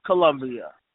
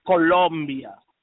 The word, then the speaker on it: Colombia: We have, on one end of the spectrum, Ms. Colombia, or Colombia, as you would pronounce it, Colombia.